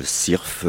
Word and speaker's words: syrphe: Eh bien voilà, syrphe. Ça s'écrit S-Y-R-P-H-E.